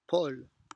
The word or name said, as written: Paul